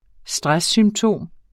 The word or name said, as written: stresssymptom